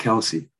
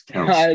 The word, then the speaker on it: Kelce: It's not Kelce. It's Kelce. – It's Kelce. – He said it. He said it himself to pronounce it Kelce.